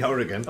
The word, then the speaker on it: Oregon: I don't know how you say it. – It's definitely Oregon.